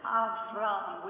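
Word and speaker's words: Avram (אברם): So Abram is spelled with, it's actually Avram with a V in Hebrew. It's spelled with four letters. So reading right to left, they are Aleph, Bet, Resh, and Mem. A-V-R-M, Avram, right?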